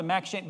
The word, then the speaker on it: M'Cheyne: What I love about the Max Shane, by the way, it's not spelled that way. It's spelled M'Cheyne. That's Max Shane.